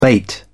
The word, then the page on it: bait: British English